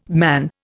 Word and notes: man: Indian

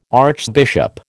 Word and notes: archbishop: American English

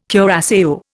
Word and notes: curaçao: Indian

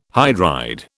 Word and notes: hydride: British English

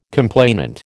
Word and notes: complainant: American English

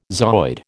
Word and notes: zooid: American English

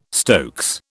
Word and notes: stokes: British English

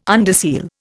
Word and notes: underseal: Indian

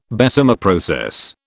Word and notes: Bessemer process: British English

